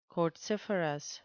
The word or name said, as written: quartziferous